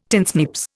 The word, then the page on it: tinsnips: Indian